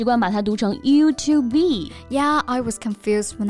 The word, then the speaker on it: YouTube: Yes, and YouTube is a compound word made up of you and tube, so it's important to pronounce each syllable separately. 所以它的正确发音应该是 YouTube.